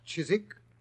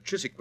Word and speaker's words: Chiswick: The Chiswick branch, to be exact. – Chiswick? – It was originally pronounced Chiswick, Watson, and it's still so spelled. It means cheese farm, I believe.